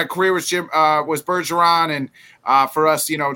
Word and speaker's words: Bergeron: Bergeron.